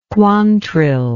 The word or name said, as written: Quantrill